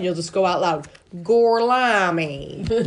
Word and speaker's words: Gorlami: They snatch up Gorlami. – Gorlami. – They throw a bag. How do you say it? – Gorlami.